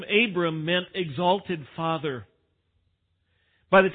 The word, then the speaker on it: Abram: Abram, in the Hebrew pronunciation, Abram.